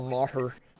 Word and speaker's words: Maher: He just I always thought it was mayor, but it's like Bill Maher.